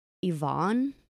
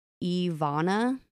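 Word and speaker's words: Eveanna: At first, I thought their oldest daughter's name was Yvonne, but I listened to a bunch of people pronounce it, and it's actually Ivana, like Eve and Anna put together.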